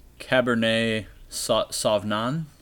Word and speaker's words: Cabernet Sauvignon: A Cabernet Sauvignon.